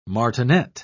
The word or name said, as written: martinet